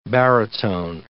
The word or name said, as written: barytone